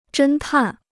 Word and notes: 侦探: zhēn tàn

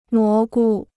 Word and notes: 蘑菇: mó gu

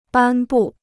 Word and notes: 颁布: bān bù

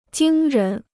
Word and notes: 惊人: jīng rén

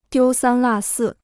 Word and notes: 丢三落四: diū sān là sì

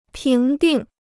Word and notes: 评定: píng dìng

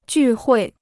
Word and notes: 聚会: jù huì

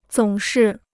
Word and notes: 总是: zǒng shì

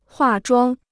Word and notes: 化妆: huà zhuāng

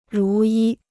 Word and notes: 如一: rú yī

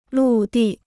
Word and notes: 陆地: lù dì

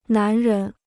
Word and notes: 男人: nán rén

